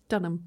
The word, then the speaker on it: Dunham: I remember Lena Dunham, if I said that right? – Dunham, yeah.